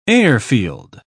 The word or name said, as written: airfield